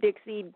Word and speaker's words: Dixie: Well, there's a story behind the name, because my name is Dixie, D-I-X-I-E. And so it rhymes with Rixie.